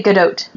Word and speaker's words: Gadot: Now, I have heard people pronounce this all sorts of different ways, but I heard somebody on a podcast who's from Israel say Gadot.